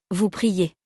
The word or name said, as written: vous priez